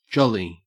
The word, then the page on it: jolly: Australian English